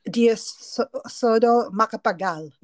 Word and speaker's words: Diosdado Macapagal: Diosdado Macapagal.